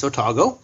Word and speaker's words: Otago: And yes, you did say it right. It's Otago.